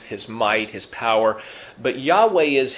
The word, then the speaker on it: Yahweh: And we pronounce it Yahweh. The Germans say Jehovah. I think Yahweh is probably a better rendering.